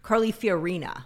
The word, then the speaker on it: Fiorina: Wait, that's not how you say her name. Carly Fiorina, right? – Yeah.